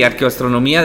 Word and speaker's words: arqueoastronomía: Sí, que creo que lo dije mal, no es arqueastronomía Sí, sí, eh, sí arqueoastronomía. De hecho, eh, ahora estamos usando más el, término astronomía cultural.